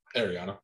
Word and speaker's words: Ariana: So Ariana DeBose actually, is it Ariana? How do you say her first name? – Ariana. – Ariana. Ariana DeBose could actually be the first female duo to do it with her.